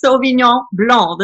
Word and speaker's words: Sauvignon Blanc: And also, neither is Sauvignon Blanc. The C is silent.